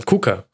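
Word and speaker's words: Kucka: Auch, Jurja, auch Jojai, Kuka, nee, es war falsch ausgesprochen, egal, Kuka. Das war definitiv richtig ausgesprochen von Milan.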